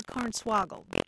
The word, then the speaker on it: cornswaggled: CORNSWAGGLED. – Interesting. Are you saying corn swaggle or corn swaggled? – We say it like corn swaggled.